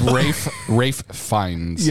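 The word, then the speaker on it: Ralph Fiennes: It's Rafe Fiennes.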